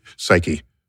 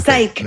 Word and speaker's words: Psyche: So it's you pronounce the E at the end, like Penelope, Psyche. – Yeah. – Right. – It's not Psyche.